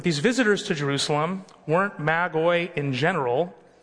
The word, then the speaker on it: magoi: The Greek word is magoi, which is plural for magos. But you can say magi if you want. I'm not going to get mad at you, but that's how it's actually pronounced, magoi.